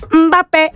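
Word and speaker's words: Mbappé: And I love the name Mbappe. Okay, now if you say it this way, it's even more fun to say, okay? You ready? You got to say it like this. Mbappe. Mbappe.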